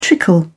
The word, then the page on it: trickle: British English